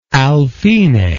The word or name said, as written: alfine